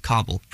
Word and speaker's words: Kabul: The capital and largest city in the country is Kabul, pronounced like cobblestone.